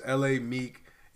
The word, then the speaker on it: L.A. Meek: But his artist name is L.A. Meek. It's pronounced the two letters L-A-M-E-E-K, like Meek Mill.